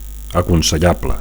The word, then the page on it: aconsellable: Catalan